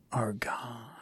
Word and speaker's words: Argonne: Argonne.